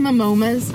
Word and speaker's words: Momoa: Oh, Jason Momoa's. Am I saying that right? – No. Momoa.